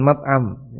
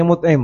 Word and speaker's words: Mut'im: Kalau penuntut ilmu sering telaah hadis, insyaallah pernah dengar nama Jubair bin Mut'im. Jangan dibaca salah, ya, jangan dibaca Jubair bin Mat'am, ya. Mat'am artinya restoran, tempat makan. Jadi cara bacanya Mut'im bukan Mat'am, ya.